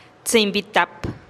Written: tsïinn bi tap

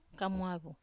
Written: kamuavu

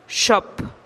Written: xop

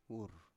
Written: wur

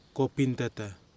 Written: Kopintata

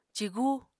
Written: cigu

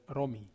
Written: romi

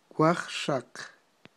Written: wajxaq

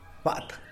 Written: baat

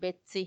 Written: betsi

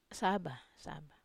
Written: saba

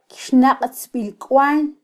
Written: kyxnaqtzbilkwal

